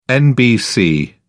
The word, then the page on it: nbc: British English